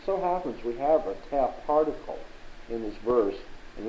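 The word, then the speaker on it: teh (τε): There's a thing called a particle in Greek grammar T-E is the way it's spelled, the teh particle.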